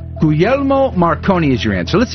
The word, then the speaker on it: Guglielmo: Guglielmo.